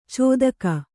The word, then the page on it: ಚೋದಕ: cōdaka